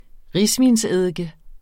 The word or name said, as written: risvinseddike